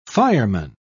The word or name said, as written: fireman